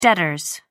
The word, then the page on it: debtors: American English